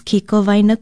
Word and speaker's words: kikowaena: Ala Moana Center, for example, is kikowaena kuai o ala moana. Kikowaena is spelled K-I-K-O-W-A-E-N-A. Kikowaena.